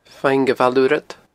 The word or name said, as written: Faing a Bhaile Urrad